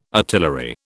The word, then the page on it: artillery: British English